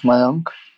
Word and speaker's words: Mayank: It's Mayank.